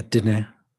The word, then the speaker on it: Diné: Yeah, Diné.